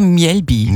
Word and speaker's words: Mjällby: Oder wie spricht man es wirklich aus? – Ja, Mjällby.